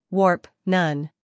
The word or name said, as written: warp